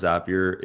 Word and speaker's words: Zapier: He says, you know, how do you pronounce Zapier? It rhymes with happier.